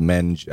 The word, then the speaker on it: Menge: It's in Past Christiane, but it's on the, what is it, the Menge? – Menge. – M-E-N-G-E. I think it's pronounced Menge.